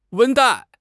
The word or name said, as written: 温带